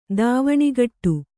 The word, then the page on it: ಧ್ವನಿಗೊಡು: dhvanigoḍu